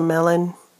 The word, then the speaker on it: mellon: Did you know your friend is a melon? Yeah. Melonamine. Melonamine. That means my friend. So yeah, don't giggle. It's spelled M-E-L-L-O-N. So it's a different melon, okay?